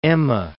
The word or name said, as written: Emme